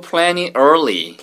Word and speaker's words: plenty: P-L-E-N-T-Y. 발음은 plenty지만 plenty처럼 들립니다.